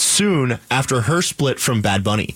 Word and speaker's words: Bad Bunny: No, Bad Bunny. You said it right the first time. Bunny. If you put the emphasis on bad, it makes it sound like there's at least one other bunny running around.